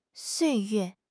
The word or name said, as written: 岁月